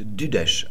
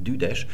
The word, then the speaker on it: Düdesch: Eigentlich hat man gesagt Düdesch, also Deutsch mit zwei Silben, Düdesch.